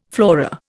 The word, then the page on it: flora: Indian